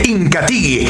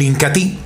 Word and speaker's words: Incatea: Incati, para relaciones más duraderas, más placenteras y para tener una próstata sana y saludable, consuma diariamente Incati, el potenciador natural. Y otro de los productos líderes de la aldea Ramazón, mis amigos, sin lugar a dudas, es el Incati. Se escribe Incatea, pero se pronuncia Incati,